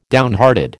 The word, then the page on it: downhearted: American English